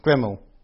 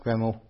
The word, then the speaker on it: GRML: GRML, according to their frequently asked questions, should be pronounced as Greml. So you may hear me refer to GRML as Greml within this audio walkthrough.